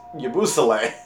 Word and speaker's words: Yabusele: Okay, we got Yabusele. – Oh, yeah, I think it's Yabusele. – Yabusele, okay, I'm sorry.